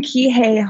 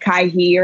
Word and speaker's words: Kihei: I live in Kihei, Hawaii. So that's specifically in Maui. It's probably good. Most people say like Kaihi or something like that.